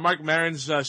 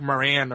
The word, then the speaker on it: Maron: Like he went to French Canada and they were like calling French Mark Maran or something. – Yeah. Mark Maron.